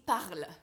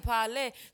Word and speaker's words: Parle: It's not Parle. – It's Parle. And I'm like, but it's spelled P-A-R-L-E. Yes. So for me, automatically, I assume that meant Parle.